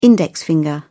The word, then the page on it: index finger: British English